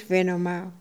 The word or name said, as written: Venoma